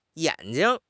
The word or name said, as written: yan3 jing5